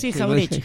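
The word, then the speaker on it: Jauretche: Y después, más contemporáneamente, Arturo Jaureche, dijo, lo dije bien, ¿no? – Sí, Jaureche.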